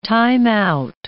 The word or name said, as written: time-out